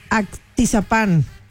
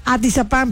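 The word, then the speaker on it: Atizapán: Anselmo de Actizapán. – Me gusta la gente que pone su nombre. – Actizapán, perdón, yo lo dije mal.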